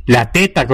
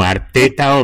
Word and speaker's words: Arteta: La teta, ¿cómo que la teta? No, Arteta, hombre, Arteta. – Y que la teta es del otro. Arteta, el Arsenal es la English Royal Society, la Real Sociedad Inglesa.